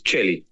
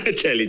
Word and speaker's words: Celi: My surname is Celi. – Celi. – Walter Celi. Celi. – Sorry, my Argentinian pronunciation. – No. Actually, it's an Italian surname.